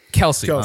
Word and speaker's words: Kelce: Is it Kelce? I forget how you pronounce his last name. – Kelce.